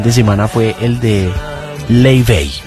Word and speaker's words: Laufey: Y su nombre se escribe Laufey, Laufey. – Sí. – Y el billboard que ha llamado más la atención de todos los observadores culturales que van a Coachella y todo, ese es el letrero que dice: se pronuncia Lay Bay.